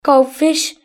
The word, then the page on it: goldfish: British English